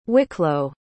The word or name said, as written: wicklow